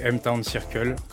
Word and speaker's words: M-Town Circle: Donc, c'est tout attaché, M-Town Circle, comme ça se prononce. Donc, M-Town, comme la ville en anglais. – Ouais. – T-O-W-N et Circle en anglais aussi.